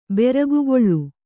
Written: ಬೆರಗುಗೊಳ್

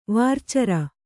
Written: ವಾರ್ಚರ

